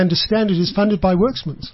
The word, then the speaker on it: Werksmans: Worksmans is spelled WERKSMANS. – Yes. – Works, Watsmans in Afrikaans. – Yes, yeah. That would be the Afrikaans version of it, yes.